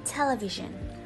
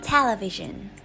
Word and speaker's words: television: Tuy nhiên thì trong thực tế, từ này khi phát âm thì trọng âm sẽ được nhấn vào âm tiết đầu tiên và sẽ được đọc là television hoặc là television.